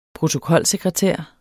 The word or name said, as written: protokolsekretær